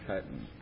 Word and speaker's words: chitin: It's spelled chitin, but it's pronounced chitin.